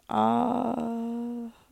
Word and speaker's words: Ahasuerus: How do you say that word? – King Ahasuerus. Sometime after,